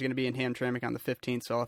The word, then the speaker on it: Hamtramck: Hamtramck, yeah.